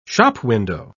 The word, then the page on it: shopwindow: British English